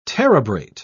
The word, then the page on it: terebrate: British English